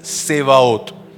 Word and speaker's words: Tzevaot: It is T-Z-E-V-A-O-T. The Hebrew word is Sebao. But when we transliterate it into English, it means, or we pronounce it as Sevaot.